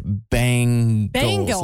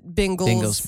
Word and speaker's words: Bengals: Our pro football team, and Big Dave is a stickler on this, is the Bengals, and people will pronounce it Bengals, like my bangs of my hair. – Yeah, and that's technically wrong. – Bengals.